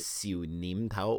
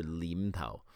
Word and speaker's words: Siu Nim Tao: Most Cantonese, they don't like to pronounce the sound of N. They change it to an L. – Right. – Hence the whole thing with Siu Nim Tao or Lim Tao. Nim is the correct educated Cantonese way of speaking it, but Lim is how most Hong Kong Chinese will say it.